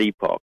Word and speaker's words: Epoch: Is it Epoch or Epoch Times? I'm not sure which. – I pronounce it Epoch.